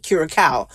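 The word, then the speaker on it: curaçao: Is that how you say it? Curacao. Yes.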